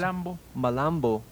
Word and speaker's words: Malambo: This song, this next song, would be the Molombo, am I pronouncing? – Malambo. – Malambo, which actually, in this case, this is Yapanqui.